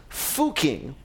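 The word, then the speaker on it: Fucking: So there's this town in Austria, and it's pretty well known because its name is Fuching, which is pronounced that way, but spelled... like a word in English that's pronounced not quite that way, if you get my drift.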